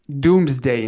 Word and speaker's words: Domesday: It's called the Domesday Book, or Doomsday in certain, I think it's, you can pronounce it.